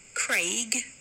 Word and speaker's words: Craig: They're spelled quite differently, and it should be Craig.